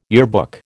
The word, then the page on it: yearbook: American English